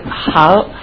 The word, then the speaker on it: Haas: I'm going to ask you how you pronounce it differently than the other Representative Larry Howes. So with that, I'd like to introduce Larry. Is it Haas? – Haas.